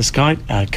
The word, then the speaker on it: Schuyt: Another iconic instrument in the collection in Utrecht is the Schuyt. Is that how you say it? – Yeah, that's yeah. – The Schuyt.